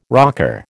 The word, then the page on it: rocker: American English